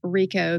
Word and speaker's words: Hricko: and it's spelled H R I C K O, but it's pronounced Rico. The H is silent.